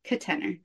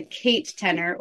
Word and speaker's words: Ketener: But I'm just pretty new to this, so it's my name, and the word tenor, and then that's just. But I'm sort of pronouncing it with a schwa in the first syllable, Ketener.